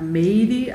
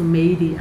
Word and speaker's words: Mary: Allerdings ähm, wird man eigentlich in Brasilien äh, Meidi ausgesprochen, wegen der Schreibweise auf Englisch. Und zwar, also letztendlich kommt der Name aus dem Mary, aus dem Englischen, mhm. wurde nach Brasilien gebracht und durch diese andere Aussprache oder dadurch die, die Brasilianer die nicht korrekte Aussprache konnte aus dem Englisch, ist der Name halt zu Meidi abgewandelt.